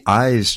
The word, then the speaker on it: Ize: And before that, we heard the Eyes Trio. It's spelled I-Z-E.